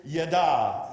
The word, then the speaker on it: yadah: The first one is yada. Everybody say yada. You spell that Y-A-D-A-H. And now it's not yada. You know, we say yada, yada, yada, yada. That means something's not important. It's yada.